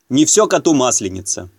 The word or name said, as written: не все коту масленица